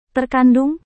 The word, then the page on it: terkandung: indonesian